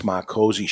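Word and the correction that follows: Micozzi: And his name is pronounced Mikosi, in case Mikosi.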